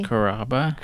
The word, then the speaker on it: Carrabba: Chris Carrabba.